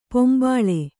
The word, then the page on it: ಪಿಂಬಳಿ: pimbaḷi; Kannada